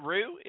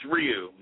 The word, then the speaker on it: Ryu: RYU? Am I pronouncing it right? Ryu? Is that how you say his name? – It's Ryu. Yeah, Ryu.